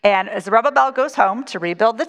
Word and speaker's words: Zerubbabel: And it's they go home under a man named Zerubbabel. Say that right? – Zerubbabel, yeah.